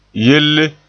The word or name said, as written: yelle